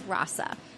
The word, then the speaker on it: Rasa: We're here at 90 Maryland Avenue at Rasa Juice Shop, and I think I pronounced that right, did I? – It's Rasa.